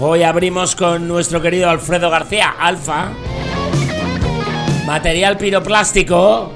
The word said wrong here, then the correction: piroclástico: Piroclástico, coño, que lo pronuncio mal.